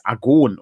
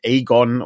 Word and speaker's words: Agon: Und die Betonung liegt auf der letzten äh, Silbe, also es ist Agon und nicht Agon oder Argon.